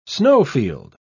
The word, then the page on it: snowfield: British English